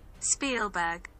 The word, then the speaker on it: Spielberg: Spielberg. – Spielberg, as in Afrikaans spiel, as in play.